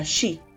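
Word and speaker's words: shi (四): Se cree que está maldito tres veces, porque el número 4 en japonés se pronuncia de manera similar a shi, que significa muerte.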